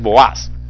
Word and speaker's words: Boaz: Cuando Salomón construyó el templo, escribió sobre las dos pilares principales, los nombres de Jaquín y Boaz, o Boaz, si usted lo quiere pronunciar en español, pero la pronunciación correcta es Boaz.